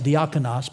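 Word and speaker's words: diakonos (διάκονος): If you were to say the word deacon in Greek, you would say it like this, deacon. Well, actually, you'd put a nominative case on it, diakonos, but it's just those letters in English.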